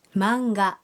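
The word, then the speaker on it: مانجا (マンガ): هذه القصص المرسومة تنطق هكذا مانجا مانجا.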